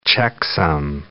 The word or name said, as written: checksum